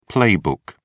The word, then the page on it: playbook: British English